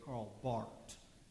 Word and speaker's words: Karl Barth: It's spelled B-A-R-T-H, but if you want to sound really important, you say Karl Barth, you know.